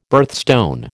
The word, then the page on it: birthstone: American English